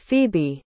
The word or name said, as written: phebe